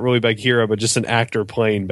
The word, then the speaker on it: Bagheera: Is that pronounced correctly? Bagheera? – Yep.